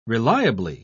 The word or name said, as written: reliably